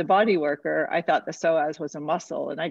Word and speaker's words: psoas: She studies the psoas, and I think she's very interesting. – And what she talks about the psoas is your deepest abdominal muscle. It's spelled PSOAS.